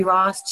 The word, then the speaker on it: Rost: Oh no, you pronounced my last name right, so that's just always a blessing. Thank you, Nori Rost.